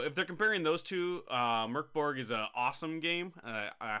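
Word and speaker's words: Mörk Borg: The author here of this piece, Chase Carter, compares it to Merc Borg, or Merc Berry, I believe it actually is supposed to be pronounced. I found that out recently. Right? But one of the creators said, just say Merc Borg, it's fine.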